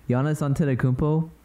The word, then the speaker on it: Giannis Antetokounmpo: Giannis Antetokounmpo for the Bucks.